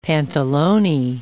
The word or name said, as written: pantalone